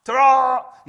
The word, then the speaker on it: Terah: Terah, is that how you pronounce it? Terah. Terah. Yeah, got it.